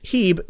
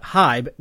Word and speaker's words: Hieb: And a minor correction from last time he joined the show, his last name is spelled H-I-E-B, which is pronounced Heeb, not Hybe, which is what I said the last time we saw him fly on STS-39, which deployed and retrieved the SPAS platform, among other objectives.